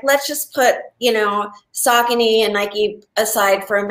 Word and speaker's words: Saucony: It's called, it's S-A-U-C-O-N-Y. – Yeah, I wouldn't know how to pronounce that. – Okay, well, most people think it's Saucony, but it's Saucony.